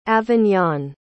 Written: avignon

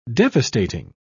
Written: devastating

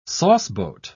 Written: sauceboat

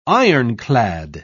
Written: ironclad

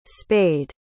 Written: spayed

